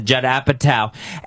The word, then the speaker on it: Apatow: Yes, that's how you pronounce it, Judd Apatow.